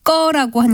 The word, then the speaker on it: 거: 이때는 된소리로 나는 꺼가 아니라 예사소리인 거라고 쓰고 발음은 꺼라고 하는 것이 맞습니다.